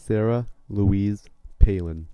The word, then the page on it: Sarah Louise Palin: American English